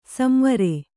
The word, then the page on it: ಸಂವರ: samvara; Kannada